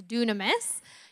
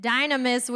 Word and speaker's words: dunamis: Yeah, so it's used in a form of power in Scripture, and the Greek word that they're really referencing is dunamis. You can also maybe pronounce it dynamis, which would make us think of the word dynamite, right? It's part of where that word comes from.